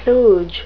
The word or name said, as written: kludge